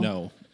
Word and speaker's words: Ngo: It's just pronounced no.